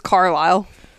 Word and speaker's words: Carlisle: And she had been talking about the book, and when she was telling me about it, she said the dad's name was Carlsley. And so the whole time I read the books, I just read it as Carlsley because that's how she pronounced it. And the name is Carlisle.